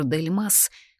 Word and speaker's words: Дель Мас: Правда, об этом знает только мой муж Артур Дель Мас, ударение на последнем слоге, это французская фамилия.